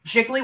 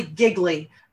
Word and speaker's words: Gigli: The wire that she uses is called Jiggly Wire. G-I-G-L-I. Jiggly Wire. – G-I-G-L-I. Like the bad Ben Affleck movie? – Yeah, except it's pronounced jiggly. Possibly giggly, but jiggly.